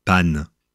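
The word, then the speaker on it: paonne: La femelle de cet oiseau suit la même règle, puisque la paone se dit panne.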